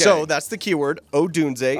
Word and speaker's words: Odunze: Is that how you say it? – It's Odunze.